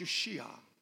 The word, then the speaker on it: Yeshua: But the name Jesus itself is actually pronounced Yeshua, Joshua, Joshua.